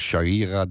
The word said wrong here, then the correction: sharia: driehoek of zo. – Sharia.